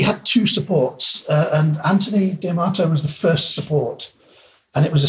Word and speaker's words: D'Amato: Is it Anthony D'Amato? – Anthony D'Amato. – D'Amato. – Pronounces it.